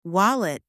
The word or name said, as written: wallet